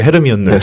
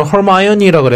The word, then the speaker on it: Hermione: 그 헤르미온느. 네. 이게 원래는 영어로는 허마이오니라 그래야 되는데 제가 뭐 그 뭐 나중에 뭐 실제 영화에서 나오는 발음을 넣든지 뭐 하여튼 할 건데.